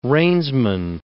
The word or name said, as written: reinsman